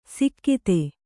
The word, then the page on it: ಸಿಕ್ಕಿತೆ: sikkite